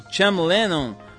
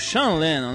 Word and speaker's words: Sean Lennon: Entre os fãs declarados dos Mutantes hoje estão o Beck, o Cham Lennon, músico e filho do John Lennon, o Sean Lennon, né? Botaram aqui umas pronúncias, botaram C-H-A-M, o Cham Lennon, é o Sean Olha a minha pronúncia, Chico.